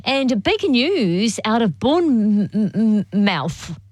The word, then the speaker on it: Bournemouth: They don't really pronounce the mouth bit. We go Bournemouth.